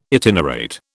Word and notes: itinerate: British English

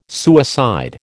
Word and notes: suicide: American English

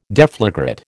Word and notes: deflagrate: American English

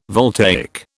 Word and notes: voltaic: British English

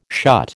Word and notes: shut: American English

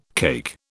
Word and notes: cake: British English

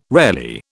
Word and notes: rarely: British English

